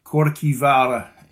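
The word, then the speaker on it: Kirkjuvágr: It's actually pronounced Korkivara in Old Norse.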